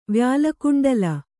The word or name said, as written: ವ್ಯಾಲಕುಂಡಲ